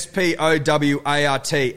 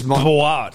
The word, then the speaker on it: Spowart: S P O W A R T. Amy with Spowart.